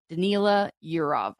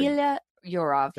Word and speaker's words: Danila Yurov: The other big exciting point for me, Danila Yurov. I hope I'm pronouncing that right because I am terrible at pronunciation. – It sounded good. It sounded very good. Convincing. – Danila Yurov.